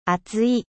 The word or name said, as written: epais